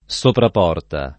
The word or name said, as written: sopraporta